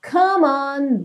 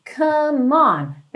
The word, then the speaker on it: come on: Começando então, come on, baby, come on. Percebe como que essas duas palavras são ligadinhas. Como é do come, não é pronunciado, você já liga direto esse M no on.